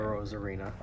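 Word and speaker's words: Arozarena: Rosarena.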